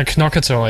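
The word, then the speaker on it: Knorkator: Hvordan udtales det? – Nokator eller knokator? – Ja, knokator, ja.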